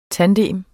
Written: tandem

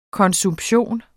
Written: konsumption

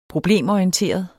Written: problemorienteret

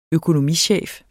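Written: økonomichef

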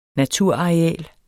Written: naturareal

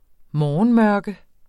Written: morgenmørke

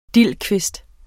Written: dildkvist

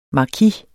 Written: markis

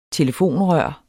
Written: telefonrør

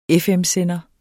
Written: FM-sender

